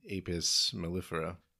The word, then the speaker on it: Apis mellifera: Scientific name, Apis mellifera. I'm hoping I pronounced that correctly. – I think you got it.